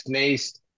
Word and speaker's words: Nijst: My name is Max Nijst. Like Lori said, if you saw my name, you would not even see that there's a long A out of the last name that I have.